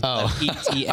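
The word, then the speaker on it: Etienne: It just literally pronounced it like ETN, and that's how you pronounce his name.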